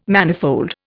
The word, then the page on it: manifold: Indian